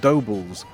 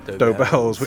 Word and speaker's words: Dobells: When I read the book, you talk about a particular record shop that was very early in Charing Cross Road, and I couldn't work out if it's pronounced Dobles or Dobells, but then on their bag, it had this slogan, which was, every true jazz fan is born within the sound of Dobells, which made me realise how it's pronounced.